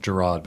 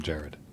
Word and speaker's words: Gerard: It's technically pronounced Gerard, but everyone calls him Jared.